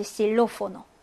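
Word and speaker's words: xilófono: 단어의 첫 글자로 올 때, 단어의 첫 글자로 엑기스가 올 때, 자, 그때는 어떤 발음이 난다? 예, 그때는 편하게 S 발음 해주시면 됩니다. 그래서 이제 실로포노.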